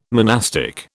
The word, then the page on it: monastic: British English